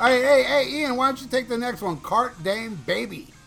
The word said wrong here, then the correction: Carpe Diem: Okay, I believe it's Carpe Diem, baby, which means seize the day, baby.